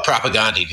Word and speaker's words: Propagandi: Do you remember, is it Propaganda or is it Propagandi? I don't know how to pronounce it. – Oh, Propagandi, dude.